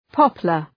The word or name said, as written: poplar